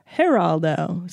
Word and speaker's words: Geraldo: Geraldo? Was it Geraldo? I'm thinking of Greg Giraldo. Sorry. I'm saying it wrong. Geraldo.